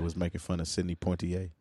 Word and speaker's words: Poitier: No, it's Poitier.